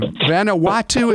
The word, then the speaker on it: Vanuatu: I never knew that's how you pronounce the country. Really, Vanuatu is how you actually say it? – That's correct. Yeah.